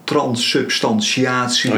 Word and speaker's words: transsubstantiatie: transsubstantiatie. – Oh, je weet het nou, wel goed uitspreken.